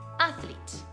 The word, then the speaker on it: athlete: In realtà questa parola, sia in American English che in British English, viene detta così: athlete, athlete. Quindi, come potete notare, l'enfasi, cioè lo stress dove io metto l'accento nella parola, è la prima A. Athlete.